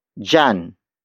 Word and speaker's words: diyan: The next demonstrative locative pronoun is dyan. Dyan. Dyan. Dyan is spelled as D-I-Y-A-N.